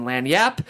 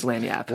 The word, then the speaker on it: lagniappe: Okay, guys, as I mentioned, we were discussing your debut novel called Love, Loss, and Lanyap. For our listeners who might not know that word is spelled L-A-G-N-I-A-P-P-E, and I'm sure you get asked a lot, what is Lanyap?